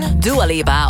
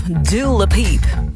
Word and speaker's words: Dua Lipa: Dua Lipa of Dua Lipiep. Zoals een Amerikaanse radio-dj ooit zei. Ze kreeg het niet uitgesproken, dus ze maakte altijd Dua Lipiep.